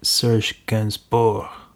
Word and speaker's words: Serge Gainsbourg: The internet tells me I've got to pronounce it Serge Gainsbourg, but it just doesn't feel right to me being from Somerset, so I'm going to say that was Melody by Serge Gainsbourg, and it's taken from his smutty 1971 concept album Histoire de Melody Nelson.